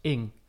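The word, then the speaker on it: Ng: Hoe spreek je haar naam uit? – Ja, ik heb... Nou, nee, nee. ik, heb, dat opgezocht. Ik, en het was heel snel, was het eigenlijk klaar. Want haar, uh, haar Twitternaam, dus haar Twitter-handle, is gewoon pronounced Ing. – Ing. Oh, Zo heet, zo, dat is letterlijk haar naam.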